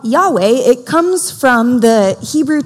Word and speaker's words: YHWH: And it translates, in Hebrew, it translates YHWH, which we pronounce Yahweh.